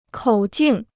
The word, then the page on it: 口径: kǒu jìng